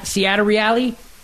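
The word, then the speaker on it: Ciattarelli: How do you say that, Matt? How do you pronounce that correctly? – Wait, say it again. I think you were close. – Seattle Reali. – I'm looking at it right now. That looks right. I think you're right. Yeah. – Yeah, I think phonetically that's probably correct.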